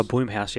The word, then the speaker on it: Blumhouse: Is it Blumhouse or Bloomhouse? – Oh, Bloomhouse, yeah.